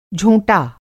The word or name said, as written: झोंटा